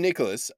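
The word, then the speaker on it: Nikolas: I am Nicholas, and it's spelt weird. Yeah, and it's spelt strange. It's N-I-K-O-L-A-S, and Stuart is S-T-U-A-R-T, which is probably...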